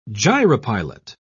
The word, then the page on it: gyropilot: British English